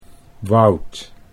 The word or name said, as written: Wout